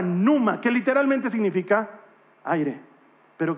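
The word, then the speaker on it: numa (πνεῦμα): Y en griego eso se pronuncia numa. Numa.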